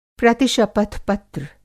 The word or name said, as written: प्रतिशपथ-पत्र